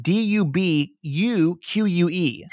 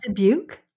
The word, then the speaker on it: Dubuque: D U B U Q U E. Dubuque.